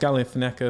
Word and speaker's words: Galifianakis: Starring Alicia Vikander, Dane DeHaan, Jack O'Connell, Tom Hollander, Zach Galifianakis. Is that how you pronounce it? – Yeah.